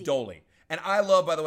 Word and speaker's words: Doli: Not, it's not Dory. It's Dolly. D-O-L-I. – Come on. – No, it is. And it's pronounced Dolly.